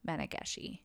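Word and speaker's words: Mannegishi: It's M-A-N-N-E-G-I-S-H-I, Menageshi.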